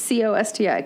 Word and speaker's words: Costi: Like C O S T I, Costi Hinn.